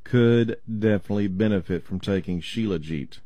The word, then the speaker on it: shilajit: they use a substance called shilajit. It's spelled J-I-T. Shilajit. Shilajit.